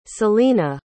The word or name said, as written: celina